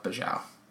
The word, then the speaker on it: Bajau: We heard it pronounced a few different ways, but we're just gonna go with Bajau. It's Bajau, and they're basically a nomadic sea people that live around the Indonesian area of the world.